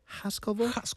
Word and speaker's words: Haskovo: It's a small town in the southern part of the country, and I don't want to ruin the pronunciation of it. Haskovo. – Perfect pronunciation.